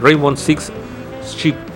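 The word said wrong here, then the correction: Siege: ¿Cómo se pronuncia? Sig. Sig. Sig. eso.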